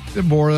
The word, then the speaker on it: DeBoer: He's obsessed with teen moms Chelsea DeBoer. How you say it? – DeBoer, that's fine.